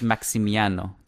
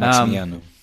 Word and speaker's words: Maximiano: Yeah, it's Maximiano. There's no L. Um, Maximiano.